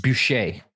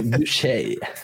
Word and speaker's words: Bucher: Bucher. Sorry, Rick. – That's the English pronunciation. Rick Bucher. – Rick Boucher. – That'd be French.